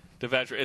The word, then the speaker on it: Devashrayee: Are we saying it correctly? – Mm-hmm. – Devashrayee.